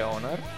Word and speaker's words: ONAR: va bene, allora direi che ci ascoltiamo gli ONAP, si legge così. – Ma Matt, si scrive ONAR.